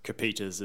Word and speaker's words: Coppieters: Coppieters is the correct pronunciation of my last name.